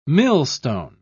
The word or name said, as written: millstone